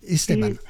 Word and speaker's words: Esteban: Esteban, comme le prénom. – Oui, d'accord, Esteban.